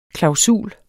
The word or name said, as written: klausul